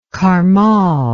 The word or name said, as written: karmal